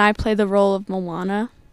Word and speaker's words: Moana: We're going to get their names and the parts that they play in the production of Moana.